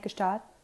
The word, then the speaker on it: Gstaad: But then this made me laugh because they had a modest wedding at his $23 million mansion in Gstaad. Is that how you say it? Gstaad? – Yeah.